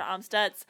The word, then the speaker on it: Amstutz: My username is at Sandra Amstutz, and my last name is spelled A M S T U T Z.